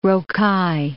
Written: rocaille

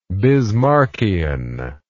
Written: bismarckianism

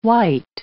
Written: wite

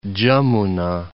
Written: Jamuna

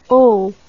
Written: eau

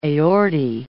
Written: aortae

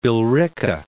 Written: Billerica

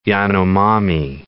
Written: Yanomami

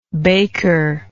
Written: bakerlike

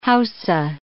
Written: Hausa

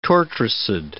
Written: tortricid

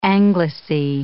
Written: anglice

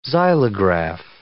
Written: xylograph

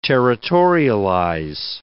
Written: territorialize

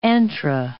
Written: antra

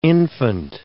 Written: infant

